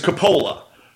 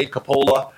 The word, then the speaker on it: Coppola: Um, there's actually three ways to pronounce it because it's a very common Italian name. We say Capola. Um, over there in Italy, they say Coppola, and there's also Coppola.